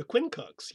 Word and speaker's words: Quincunx: The Quincunx, yeah. – Well, there you go. So the word Quincunx, I mean, is it pronounced the way it looks? Quincunx? – Yes.